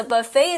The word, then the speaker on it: buffet: Because buffet, although it's spelled with two F's, at the end we have a word we don't, a letter we don't pronounce. – So after the two F's, we have E, and then in the end, don't forget about T. – Right.Buffet, the T is silent though.